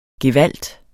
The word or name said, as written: gevalt